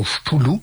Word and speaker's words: Cthulhu: Cthulhu. Et dans ce mythe de Cthulhu, c'est un peu difficile à dire parce que ça s'écrit C-T-H-U-L-H-U.